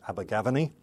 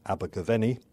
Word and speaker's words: Abergavenny: Some people pronounce it Abergavenny. But most people in England and most of the Welsh people I know pronounce it Abergavenny.